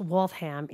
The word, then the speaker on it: Waltham: The picture of our meetup in Waltham, which I'm told it's pronounced Waltham, even though there's only one H, but okay.